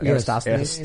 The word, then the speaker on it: Eratosthenes: Eratosthenes.